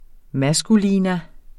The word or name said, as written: maskulinum